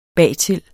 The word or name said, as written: bagtil